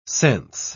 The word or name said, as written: sense